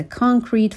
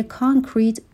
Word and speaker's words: concrete: Gradually, this will help you notice patterns over time and the concrete factors that contributed to a certain mood. به تلفظ کلمه concrete دقت بکنین. در ابتدا صدای شوا رو نداره، صدای آ رو داره.